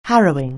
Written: harrowing

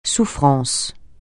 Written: souffrance

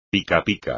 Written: picapica

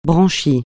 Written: branchies

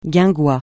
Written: guingois

